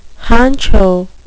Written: honcho

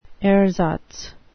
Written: ersatz

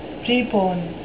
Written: prepone

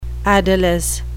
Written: adolesce

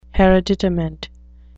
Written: hereditament

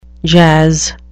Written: jazz

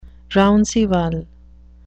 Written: rounceval

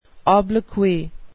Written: obloquy

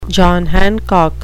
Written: john hancock

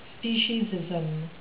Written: speciesism

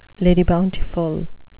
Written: lady bountiful

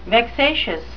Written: vexatious

